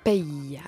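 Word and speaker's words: Pey: Pay. Ah oui, c'est ça, je me suis demandé si qu'est-ce qu'on faisait avec ce Y. – On le prononce.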